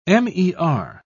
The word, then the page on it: mer: British English